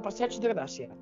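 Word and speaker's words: Passeig de Gràcia: Sí, sí, lo has dicho bien, del Passage de Gracia. Lo has dicho bastante bien.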